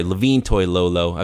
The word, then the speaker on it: Toilolo: And I think the 49ers in the offseason signing Levine Toilolo, uh, Levine, I'm sorry, Levine Toilolo. I've been calling him Levine for so many years, it's hard for me to correct it because for some reason I never said his name properly.